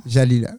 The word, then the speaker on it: Jalila: Jalila.